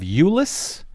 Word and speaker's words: Euless: A polling site in the Fort Worth suburb of Euless. Am I saying that right? – Yes, you are.